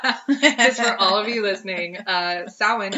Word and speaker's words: Samhain: I learned that the Glenn Danzig band Samhain should be technically pronounced Samhain, and